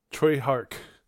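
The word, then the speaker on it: Tsui Hark: And Knock Off was made by a famous, which I didn't know at the time, I know now, a famous director named Hark Sua. Am I saying that right? – Troy Hark. – Yeah, Troy Hark.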